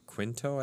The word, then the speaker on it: Quinto: But the new one has like Chris Pine, Zoe Saldana, Simon Pegg, Zachary Quinto, I think I'm saying that right.